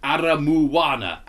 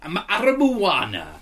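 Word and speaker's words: Aramoana: Aramona, pronounced Aramuwana. Aramuwana.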